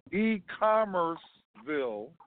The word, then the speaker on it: E-Commerceville: Robin, break down the name so they can get it phonetically. No, Robin's not. Yeah. Anyway, E-Commerceville. E in commerce, the word commerce, and then Ville, V-I-L-L-E, one word. E-Commerceville.